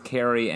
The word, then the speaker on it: Carrie: It's pronounced Carrie and Kerry. – Right. – It's yeah. Carrie. You're saying and Kerry. The same goddamn name twice. – No, no, no. There's a very distinct difference between the two pronunciations and the two characters.